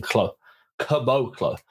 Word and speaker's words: Caboclo: K-U-H, Bo, emphasis on the Bo. And then Caboclo.